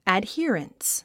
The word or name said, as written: adherence